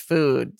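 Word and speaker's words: füde: First of all, it's called food, which is annoying because it's a combination of food and nude, but it's F-U-D-E. And I'm like, but it's still pronounced just food.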